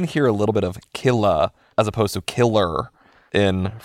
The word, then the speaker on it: Killah: I need to note that the title of Killa by Lady Gaga is spelled K-I-L-L-A-H, hence the pronunciation.